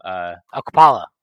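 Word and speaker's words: Okpala: Akpala. – KZ Akpala, and he didn't get like a fair bet, and now like he might get moved, and he's going to be collateral damage.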